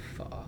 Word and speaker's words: pho: I don't want to be like a douchebag, but actually it's pronounced pho.